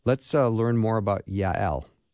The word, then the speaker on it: Jael: Now, when I started this interview, I called her JL or Jail. So I've been corrected twice. The first one was JL, and the proper Hebrew pronunciation is Yael.